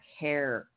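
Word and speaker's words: Herer: I have to repack yourself a bowl, but it's Jack Hare. Rhymes with tear. That's how they said to say it.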